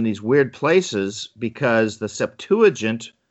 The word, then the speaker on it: Septuagint: Septuagint.